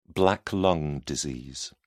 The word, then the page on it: black lung disease: British English